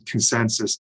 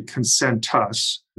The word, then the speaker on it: Concentus: Yeah, people very often pronounce it Concentus. It's actually Concentus. There's a T in there.